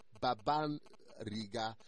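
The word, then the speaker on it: babbariga: You know, it's pronounced as babariga. That's B-A-B-B-A and then R-I-G-A.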